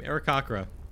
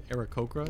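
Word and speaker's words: Aarakocra: Oh, we say that the same. Arakakra. I think a lot of people say Arakokra is what it's technically pronounced. Arakokra. – There's no H, though. – There is an H. No, I mean, there isn't an H. I was looking at the phonetics. There is not an H.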